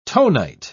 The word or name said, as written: tonite